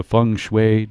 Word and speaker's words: feng shui: Now, today we're going to talk to a feng shui. Do you say feng shui or feng shui? – Feng shui. – So it's, even though it's spelled F-E-N-G, it's pronounced feng. – Yeah, it's like fungus.